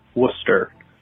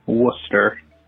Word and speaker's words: Worcester: You've mispronounced it wrong a few times. It's Worcester. Very simply, Worcester.